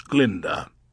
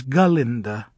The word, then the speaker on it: Galinda: "Let me be the first to welcome you to Shiz, Glinda. This is your first year?" – "Please, it is Galinda. The proper old Gilliconese pronunciation, if you don't mind."